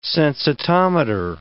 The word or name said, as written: sensitometer